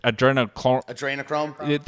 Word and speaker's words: adrenochrome: Adrenochrome.